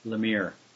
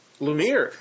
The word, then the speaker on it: Lemire: Lemire. – Lemire, awesome.